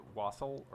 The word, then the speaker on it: Wassail: Is it Wassail or Wassail or something else entirely? – You know, that's also... up for debate. It's an old English word, and the pronunciation is most similar to West Hale.